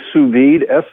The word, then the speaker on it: sous vide: It's sous vide, SOUSVIDE.